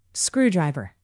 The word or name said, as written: screwdriver